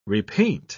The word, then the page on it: repaint: British English